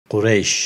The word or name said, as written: Qureysh